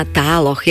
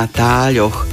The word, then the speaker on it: táľoch: Ak mám odpovedať na túto otázku, tak si môžeme pomôcť pravidlami slovenskej výslovnosti a tam je slovo tále zapísané s mäkkým ľ. Výslovnosť je zapísaná s mäkkým ľ. To znamená, že aj v tvaroch pri skloňovaní používame mäkké ľ, čiže tále, i keď v bežnej reči málo kto, i keď mnoho používateľov jazyka určite áno, vyslovuje to ľ pekne mäkko, ale v ďalších tvaroch už musíme zapísať mäkké ľ s mäkčením, čiže správne je na táľoch, podobne aj v ostatných tvaroch.